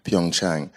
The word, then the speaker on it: Pyeongchang: Pyeongchang. Did I say that right? – You did.